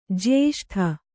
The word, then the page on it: ಜ್ಯೇಷ್ಠೆ: jyēṣṭhe